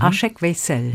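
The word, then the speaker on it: Aşık Veysel: Aschük Weissel, ist das richtig ausgesprochen? Aschek Weissel. – Mhm. Aschek Weisel. – Mhm. Weisel. Ja.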